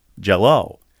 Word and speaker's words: Jello: My aunt would pronounce jello with the emphasis on the O. Jello. – Jello.